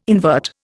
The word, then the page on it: invert: Indian